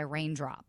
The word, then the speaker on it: Reigndrop: She has a younger brother, Ronald Jr., and a younger sister, Raina, who goes professionally by Raindrop. And that's spelled R-E-I-G-N-D-R-O-P.